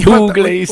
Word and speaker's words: Buckley: Come io quando pronuncio Jeff Buckley, Bagley, Bagley. – Bagley, Bagley, sì, Buckley, sì, Buckley. Tio si adatta ai musicisti perché è loro amico.